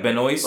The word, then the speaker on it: Benoist: It's like Benoist. – Whoa. – It's spelled like Benoit. But it's like Benoist or something like that.